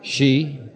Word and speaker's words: ski: She? – That's the right way to pronounce S-K-I, George.